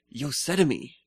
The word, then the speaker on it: Yosemite: So, also at the WWDC, we got to see the OS X new preview of the now, not Mavericks, but Yosemite.